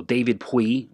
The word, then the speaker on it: Puig: So David Puy, which is spelled P-U-I-G. He's Spanish, so that's kind of how they say it.